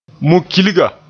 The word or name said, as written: mu-kilga